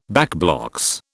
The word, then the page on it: backblocks: British English